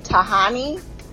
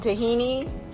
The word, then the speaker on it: Tahani: So first I want to say her name is pronounced Tahani. I don't know why you keep saying Tahini. Probably maybe you're seeing it spelled. I already remember the names because I watched all 4 seasons, so I remember how to say it, but it's Tahani.